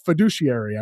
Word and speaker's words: fiduciary: But fiduciary, I believe that is the proper pronunciation for what it Jordan is.